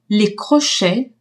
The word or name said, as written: crochets